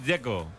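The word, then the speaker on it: Džeko: Dzeko. Bruno dice que es Dzeko. – Como tirando a J, Dzeko o algo así.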